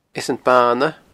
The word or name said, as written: Eas an Bana